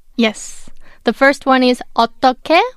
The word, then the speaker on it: 어떻게: The final consonant of the second syllable is 히읗, 어떻, and the last syllable is 게, 어떻게, but it's pronounced as 어떻게. Because when 히읗 meets 기억, it's combined and pronounced as 기억, so 어떻게.